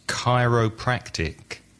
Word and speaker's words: chiropractic: The next one begins with a CH, but I know that it's a K sound. It's chiropractic.